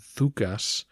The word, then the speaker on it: Doukas: For those of you who are binge listening, he does seem to be descended from the same Doukas family who rebelled under Leo VI. And FYI, in Greek that name should sound more like Thukas, but I'll continue with the pronunciation we've established.